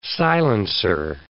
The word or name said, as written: silencer